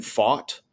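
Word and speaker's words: Pfaadt: I mean, this poor young man, I've been purposely mispronouncing his last name all year. But Brandon Fought. – You got it. – That's it.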